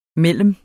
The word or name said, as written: mellem